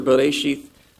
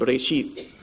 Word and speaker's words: Bereshith: That little light blue, that's a yod. It's normally a consonant, but in this case, it's used to indicate that it should have the e sound at the end, Bereshith.